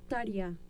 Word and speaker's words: Tarja: Tarja, se pronuncia.